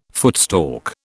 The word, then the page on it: footstalk: British English